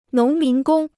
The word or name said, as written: 农民工